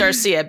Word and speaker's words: Darcia: It is Darcia because it rhymes with sangria.